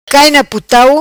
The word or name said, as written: Putau Cai Na